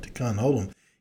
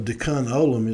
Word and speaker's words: Tikkun Olam: Now, for my Jewish friends that are listening, you know that my pronunciation of Tikkun Olam is I was raised on phonetics and understanding, so I don't say it the way the Jewish, but for me, it's spelled T-I-K-K-U-N, and then the next word is O-L-A-M, so I call it Tikkun Olam.